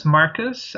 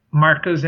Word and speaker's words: Marcos: Well, but you can pronounce my name as Marcos, basically, as we say, Marcos in English, because Portuguese Brazilian has very, I'm not going to go much into that, but like we have a lot of different pronunciations. And by chance, the way you pronounce my name in my hometown of São Paulo is pretty much Marcos. So it's very, very similar to English.